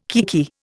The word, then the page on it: kiekie: Indian